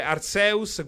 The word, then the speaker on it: Arceus: In Giappone si legge Arceus, quindi direi Arceus.